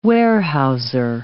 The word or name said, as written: warehouser